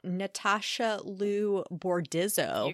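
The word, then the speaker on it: Natasha Liu Bordizzo: We are talking about Natasha Lou Bordizzo.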